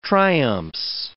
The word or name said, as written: triumphs